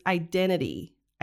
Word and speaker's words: identity: Yeah, so here she uses the stop T for the T in the middle of identity. So she doesn't pronounce that T in the middle. So she says identity, identity.